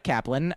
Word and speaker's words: Kaplan: My name is Mike Kaplan.